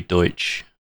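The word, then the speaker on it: Deutch: My understanding is that it's Zoe Deutsch, such as like Deutschland, which is what it's spelled exactly like.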